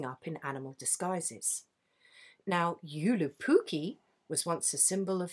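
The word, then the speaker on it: Joulupukki: Yulupukki? – Yulupukki.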